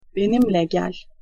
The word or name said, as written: benimle gel